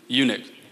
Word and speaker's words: eunuch: Enoch. Enoch, is that right? Is that how you say it? Eunuch. That's what I meant to say.